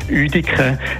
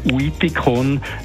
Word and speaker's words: Uitikon: Die heutige Aussprache Uediken, die repräsentiert die alte Aussprache, also die, die richtige Aussprache, wenn man so will, während dem Uidikon ist eine falsche Aussprache nach einer mittelalterlichen Schreibweise. Im Mittelalter, also im Mittelhochdeutschen, im Hoch- und Spätmittelalter hat man nämlich die ähm, Schreib- oder die Zeichenfolge UI gebraucht, um zum können «ü» sagen.